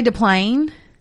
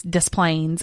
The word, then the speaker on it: Des Plaines: I always want to say Des Plaines. – Yeah. – And I guess that's because we live in Louisiana and we don't pronounce S's a lot of times. – Right. – But in everything I've ever watched, it's Des Plaines, and that hurts my ears for some reason.